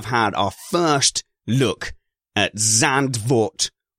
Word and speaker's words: Zandvoort: The one I always go with is Zandvoort because that's how it's spelt and it's the one I hear the most.